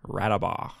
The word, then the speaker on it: Radebaugh: And my name is Connor Radebaugh. – Cool. – Say your last name again. – Radebaugh.